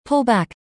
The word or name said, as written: pull back